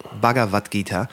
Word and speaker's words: Bhagavad Gita: Das hat diese, diese, diese, ähm, es wird ja auch irgendwann diese äh, Bhagavad Gita spricht man das, glaube ich, aus, Bhagavad zitiert. Gita, ja. – Bhagavad Gita.